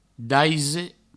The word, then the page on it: daise: Provençal